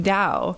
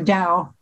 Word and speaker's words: Tao: And it is okay to say Tao, or is that disrespectful? – That's probably the American way of saying it. And the Chinese would pronounce it more like with a D instead of a T.